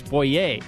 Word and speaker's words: Boyer: It's Boyer. Boyer, right? – Yeah, that sounds right. – Charles Boyer. But it's spelled B-O-Y-E-R, so it's kind of... – That's like French or something.